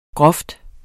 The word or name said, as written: grov